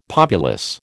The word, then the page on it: populace: American English